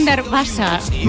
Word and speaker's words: Unterwasser: Me lo han corregido antes, Unterwasser. Es Unterwasser. – ¿Wasser? – Sí, Víctor. – Víctor que sabe alemán, sí, inglés, italiano. – Sí, hasta me ha dicho Unterwasser.